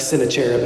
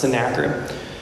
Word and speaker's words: Sennacherib: Looks like Sennacherib if you pronounce it phonetically, but it's pronounced Sennacherib.